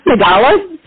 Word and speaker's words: Tagalog: It's pronounced Tagalog, so it's that Girl Scout cookie called Tagalongs? It's basically, Tagalog, without the N.